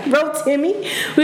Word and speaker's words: Rotimi: So as you heard from our intro song, we were playing some Rotimi. Is that how you pronounce this? – Yeah. – Rotimi.